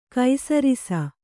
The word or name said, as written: ಕೈಸರಿಸ